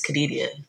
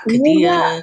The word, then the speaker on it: Kadidia: okay, it's Kadidia, not Kadidia, anything, like just read it.